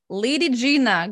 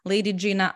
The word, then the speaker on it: Ladygina: Ladygina, going back to my last name, it's very easy to pronounce that in Canadian language, right?